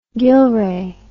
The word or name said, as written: Gillray